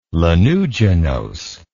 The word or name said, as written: lanuginose